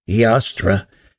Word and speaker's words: Eostre: alternately spelled E O S T R E and pronounced Yastra,